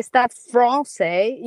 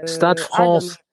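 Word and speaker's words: Stade Français: No, as you say, Stade Francais, you put like an L instead of an R. You know, in French, we like pronouncing a R comme like... – Stade Francais.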